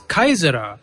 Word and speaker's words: Caesar: In fact, later Kushan kings even called themselves Kaisara, a copy of the Roman title Caesar, which is correctly pronounced as Kaisar.